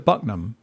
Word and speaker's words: Buckingham: Buckingham. – Buckingham. Locals would call it Bucknum.